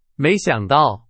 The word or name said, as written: 没想到